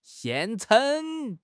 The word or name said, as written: 现成